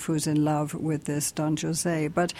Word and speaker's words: Don José: You asked why I mispronounced Don Jose. Right? It's into a cultural thing here. Bizet is French. And the opera is in French. – And the opera is in French, not in Spanish. – That's why he's called Don José.